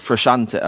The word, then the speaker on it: Frusciante: John Frusciante. – Frusciante, okay, that's how you say it, right.